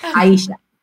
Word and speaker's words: Aesaha: So she tried to spell it Spanish. A-E-S-A-H-A. Aisha. So that's why my name is Aisha.